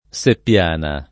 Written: Seppiana

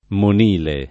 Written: monile

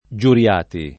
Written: Giuriati

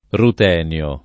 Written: rutenio

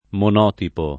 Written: monotipo

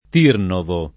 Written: Tirnovo

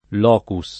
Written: locus

